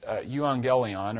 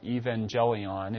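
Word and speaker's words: euangelion (εὐαγγέλιον): The word gospel there is euangelion, or evangelion, if you want to take the Latin kind of pronunciation.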